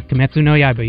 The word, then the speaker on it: Kimetsu no Yaiba: I'm going to try to pronounce this. Demon Slayer, Kimetsu no Yaiba. – Yeah, Kimetsu no Yaiba. You got it.